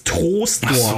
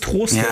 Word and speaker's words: Troisdorf: Ich weiß nicht warum, aber man spricht es Troisdorf. – Ach so, ja. – Ich weiß, es schreibt sich OI, aber warum auch immer spricht man es Troisdorf.